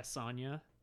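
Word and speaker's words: Sonja: Yeah, Sonya. Sonya. – Yeah, the way they phrase it is the questionable mother parenting. – Yeah. – With the sexy silent J.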